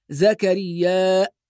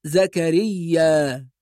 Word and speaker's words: زكريا: بعض الناس ينطق كلمة زكريا بهمزة بعد الألف فيقول زكريا، وهذه قراءة صحيحة، ولكن رواية حفص التي نقرأ بها بدون همز فتقرأ زكريا.